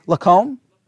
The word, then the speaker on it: Lacombe: Francois, and would you say Lacombe? Lacombe, all right. I got that right.